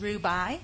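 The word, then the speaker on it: Rubaii: My name is Safia, and my last name's Rubai. R-U-B like beautiful, A-I-I.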